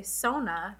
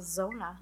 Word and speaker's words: Zona: I will probably say Sona, because that is how it is spelled. But it is Zona.